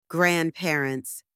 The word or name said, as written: grandparents